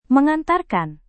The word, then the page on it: mengantarkan: Indonesian